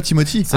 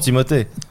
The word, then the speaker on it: Timothée: En plus c'est Timothée, il s'appelle Timothée, il s'appelle pas Timothy. – Ah ouais. – Bah oui, on prononce Timothée.